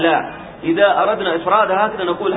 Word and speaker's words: لا: فنطقها مثل نطق حرف النفي المعروف لا، حرف النفي المعروف الذي هو لا.